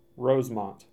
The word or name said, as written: Rosemont